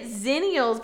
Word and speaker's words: Xennials: well, I was pronouncing it Xennials because I think X has a Z sound.